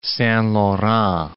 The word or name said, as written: Saint-Laurent